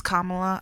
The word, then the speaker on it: Kamala: Is that how you say her name? – I think it's Kamala. – It is Kamala. I always say it wrong the first time and I know I'm wrong.